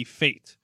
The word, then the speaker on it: fete: Fate. It's spelled F-E-T-E, Marlo. This is apparently a word that ESPN is pronounced fate.